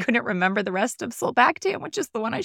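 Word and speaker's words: sulbactam: Solbactam, Durlobactam.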